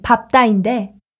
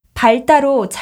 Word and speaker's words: 밟다: 이 밟다의 표준 발음은 밟다인데 흔히 밟다로 잘못 발음하는 경우가 있습니다. 그 이유는 밟다를 활용하면 밟아, 밟으니와 같이 발음되므로 발음을 혼동하기 쉽기 때문입니다.